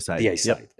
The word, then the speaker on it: Deicide: And I remember listening to Morbid Angel and, how do you pronounce it? Deicide, you know, the Glenn Benton band. – Deicide. – Deicide. Yep.